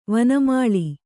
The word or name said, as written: ವನಮಾಳೆ